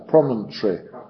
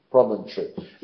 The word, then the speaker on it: promontory: La penúltima sílaba pronunciado en americano de los Estados del Sur, promontory, ya con todas las sílabas. La penúltima débil es el tree, ¿ya? Eso es lo que se pierde. Se convierte en tree, como árbol, tree, promontory. Y eso es exactamente como lo pronunciamos en inglés moderno, promontory.